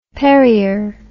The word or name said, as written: perrier